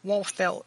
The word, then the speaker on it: Wolfelt: Wolfelt. It's spelled W-O-L-F-E-L-T.